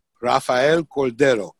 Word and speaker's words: Rafael Cordero: And yeah, it's Rafael Cordero, C-O-R-D-E-R-O. Rafael Cordero. – Type in public schools in Puerto Rico, it should pop up something on Wikipedia. – Or if you just do Rafael Cordero, boom, you're going to get it.